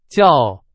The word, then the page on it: 叫: Chinese